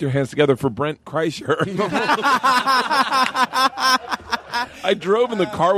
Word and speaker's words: Kreischer: She comes up to me afterwards before the show, and she's like, Kreischer. Kreischer. Am I saying that right? Kreischer. I said, yeah.